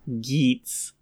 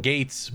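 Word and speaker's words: Geats: Geets. – Hate that it's pronounced Geets instead of Gates, but what are you gonna do? – God, that suit's so fucking good.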